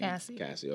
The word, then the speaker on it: Cassie: Cause I know I had a conversation with the fellas a couple days ago about this where, you know, Cassie, or Casey, I don't even know how to say her name. – Cassie. – Okay.